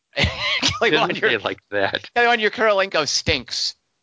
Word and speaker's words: Kurilenko: Olga Kurilenko. Right. It took me a moment. – Kurilenko.